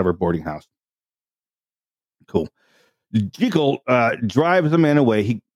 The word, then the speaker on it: Jekyll: There's a whole bunch of scenes, you know, with Muriel and Dr. Henry Jekyll. I'm sorry, it's not even Jekyll. It's correctly pronounced Jekyll, which is something I bet you didn't know. That's the correct pronunciation is Dr. Jekyll.